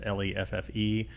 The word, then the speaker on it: Leffe: It's either Leif Blonde or Leffe Blonde. It's Leffe.